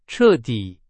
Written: 彻底

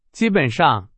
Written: 基本上

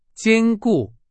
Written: 坚固

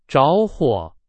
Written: 着火

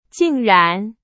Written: 竟然

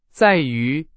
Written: 在于

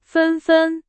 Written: 纷纷

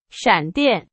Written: 闪电

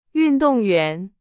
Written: 运动员

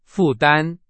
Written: 负担

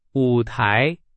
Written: 舞台

